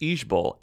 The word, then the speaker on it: IJBOL: Though it may sound like a Korean word or the name of a new boy band, IJBOL, pronounced IJBOL, actually stands for I just burst out laughing.